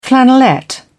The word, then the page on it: flannelette: British English